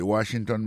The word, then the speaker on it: Washington: Si n'a pas mis accent sur accent encore, on va voir que mot Washington, là, accent tombé sur premier syllabe là. Pour mot monument, il tombé sur premier syllabe là, tout. The Washington Monument.